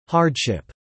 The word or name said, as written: hardship